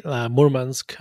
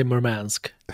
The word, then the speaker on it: Murmansk: I think Russians say Murmansk. Americans say Murmansk.